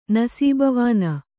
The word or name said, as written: ನಸೀಬವಾನ